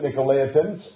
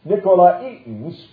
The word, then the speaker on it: Nicolaitans: Thus you also have those who hold to the doctrine of, we pronounce it, Nicolaitans, and it's Nicolaitans, which thing I hate.